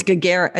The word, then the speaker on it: Gagarin: Blue Jackets, news today, Igor Chinnikov and Avangard Omsk have won the Gagarin Cup. Did I say that right? The cup? No, it's Gagarin.